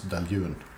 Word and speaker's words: Dailuaine: Skal det sies for øvrig at navnet på dette destilleriet, som staves D-A-I-L-U-A-I-N-E og uttales Deljun, mm -hmm.